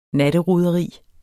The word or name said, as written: natteroderi